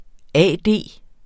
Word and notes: A.D.: ˈæˀˈdeˀ

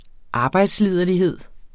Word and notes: arbejdsliderlighed: ˈɑːbɑjds-

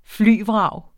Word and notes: flyvrag: ˈflyˀ-; ˈfly-

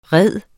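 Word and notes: ride: verbum

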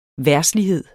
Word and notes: verdslighed: ˈvæɐ̯sliˌheðˀ; ˈvæɐ̯dsliˌheðˀ

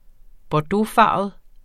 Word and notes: bordeauxfarvet: -ˌfɑˀvəð; -ˌfɑːvəð

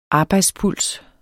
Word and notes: arbejdspuls: ˈɑːbɑjds-

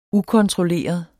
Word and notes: ukontrolleret: ˈukʌntʁoˌleˀʌ; ˈukɔn-; ˈukon-

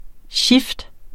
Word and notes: shift: ˈɕifd; ˈɕefd

